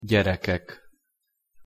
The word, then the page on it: gyerekek: Hungarian